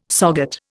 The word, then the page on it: SOGAT: Indian